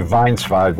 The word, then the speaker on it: Weinzweig: Well, if we were saying it in Yiddish or German, we would say Weinswig, which is probably the proper way.